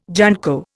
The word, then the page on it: junco: Indian